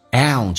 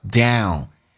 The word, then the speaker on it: down: See at your 叫 at your， T 加也发 ch 这个发音叫做 at your， at your downstairs， down 这个发音特别难发，首先发 a 再发 u，美式发音跟英式发音有不一样的，它发的首先是梅花音加个 u，这个发音叫 down downstairs。